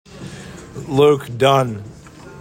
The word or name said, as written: LUKE DUNNE